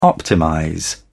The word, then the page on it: optimize: British English